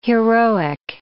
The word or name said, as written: heroic